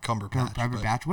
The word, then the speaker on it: Cumberbatch: It has everything to do with Benedict Cumberbatch. Like he was just totally What is it? Did I just say it wrong? – Cumberbatch.